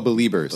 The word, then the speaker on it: Beliebers: Bebreebers. – Bebreebers? Yeah. Oh, Beliebers.